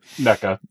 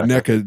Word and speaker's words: NECA: I think it's NECA. – NECA. – Yeah, NECA.